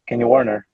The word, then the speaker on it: Kenny Werner: Kenny Warner, falando a pronúncia.